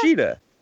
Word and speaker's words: Shida: I mean, her name is Sheeta. It's Hikaru Shida.